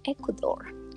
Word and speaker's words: Ecuador: So, yeah, that's the Spanish pronunciation. When I said it like that, no one will understand me, so... Especially when I was living in London, I had to say it with the accent, like Ecuador, so they will understand.